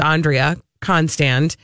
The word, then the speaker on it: Constand: We've been saying her name wrong, I've just learned the whole time. I guess it's Andrea Constand.